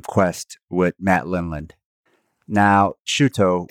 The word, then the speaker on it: Shuto: The reason why it's called Shuto is because it's the Japanese pronunciation of shoot.